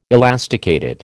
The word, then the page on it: elasticated: American English